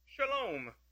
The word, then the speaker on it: shalom: Well, the Hebrew word here rendered welfare is pronounced, the famous word we may know, shalom.